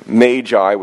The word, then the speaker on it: Magi: So first we take up the Magi. I do believe that's the correct pronunciation, although some people say magi or other things.